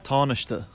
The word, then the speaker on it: Tánaiste: Tánaiste. – Pisze się Tánaiste, but it's not Tánaiste.